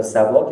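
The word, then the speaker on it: سبأ: عجب عجب عجب سوره سبع یا سبا که سبع تلفظ صحیح ترش هم اومد حالا چون رایج شده دیگه بنویسم سبا.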